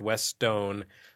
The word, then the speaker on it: Westone: I checked out the Japanese Wikipedia page. It is pronounced Westone. There's confusion over it's West One or Westone.